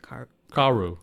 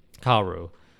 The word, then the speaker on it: Kaoru: But yeah, it's Rilakkuma and, now I can say her name. Koro? – I think it's Kaoru. – Okay. – Kaoru.